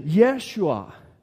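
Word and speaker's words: Yeshua: Det heter Jeshua. Vilket är namnet Jesus på hebreiska? Vi har en grekisk knorr på vårt uttal av Jesus.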